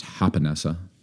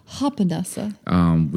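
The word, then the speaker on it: Japonessa: We started out at dinner at a great sushi bar here locally in our area called, well, it's spelled Japanessa. I think it's pronounced Hapanessa. – Hapanessa.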